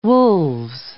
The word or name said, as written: wolves